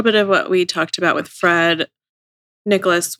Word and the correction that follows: Nicolaus: was... – Nicolaus. – I'm sorry, it's Nicolaus.